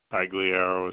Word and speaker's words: Pagliaro: Correct, M-I-C-H-A-E-L. – Thank you. Can you spell your last name for me, please? – Sure. Pagliaro is spelled P-A-G-L-I-A-R-O. – I'm sorry. Let me just verify. P-H-G?